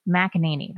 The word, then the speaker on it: McEnany: Kayleigh McEnany. That's right, right? – Yes.